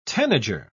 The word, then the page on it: tanager: British English